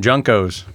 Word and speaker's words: JNCO: Junko. – You, how do you say it? – We always called them Junkos.